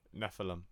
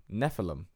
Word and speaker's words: Nephilim: Nephilim. – Nephilim.